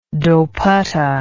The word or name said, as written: dopatta